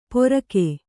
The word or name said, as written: ಪೊರಕೆ